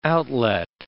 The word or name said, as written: outlet